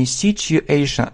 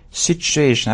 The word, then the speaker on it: situation: This situation, обратите внимание на произношение. Не situation, а sh, situation. Об этом многие не знают и делают ошибки в произношении.